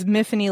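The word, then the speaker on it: Mifanwy: So it's about a woman named, I never remember how to say it, Miffany? I say it Miffany in my head, but it's spelled in a very, is it Welsh? – Yeah, if I remember correctly, the going, like, thought was Miffany like Tiffany.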